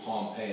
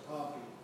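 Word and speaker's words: Pompeii: What happened to the city of Pompey 100 years later? – It's actually pronounced Pompey. – Okay. – The general was Pompey, the city is Pompey.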